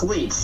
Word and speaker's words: Leach: But yeah, it's Leach. Just okay. Like the little parasite stuck on your...